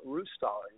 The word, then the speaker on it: Roostaller: It rhymes with who's taller, Roostaller. You got it right.